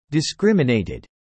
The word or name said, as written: discriminated